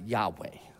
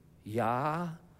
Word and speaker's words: Yahweh: And it's we pronounce it Yahweh. Actually, some people pronounce it Haya, like a karate chop, all right? But in Hebrew, what it's supposed to sound like is an inhale and an exhale. Yahweh.